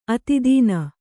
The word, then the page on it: ಅತಿದೀನ: atid īna